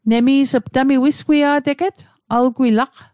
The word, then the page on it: wisgwia'teget: Mi'gmaq